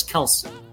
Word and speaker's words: Kelce: We've all been saying his name wrong, apparently. They had Travis on the thing. He said, my last name is Kelce.